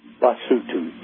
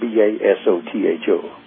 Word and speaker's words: Basotho: And then all the O's are pronounced ooh. – Ooh. And then the people, the people are called what? – Basotho, Basotho.